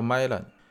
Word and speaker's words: Mylan: 哎，那 IP pen 的这个厂商啊，啊叫做美林或者叫麦兰啊，它反正它那个英语的发音叫 Mylan，他给出的解释啊，说为什么我这个东西要十二到十八个月以后就要过期呢？